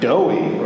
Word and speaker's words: Dowie: It's spelled D-O-W-I-E, so it's going to be like Dowie or some shit. – Sir, it's Dowie, like you said, right?